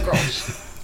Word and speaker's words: Grolsch: Grolsch or something. – Yes. – It's Grolsch.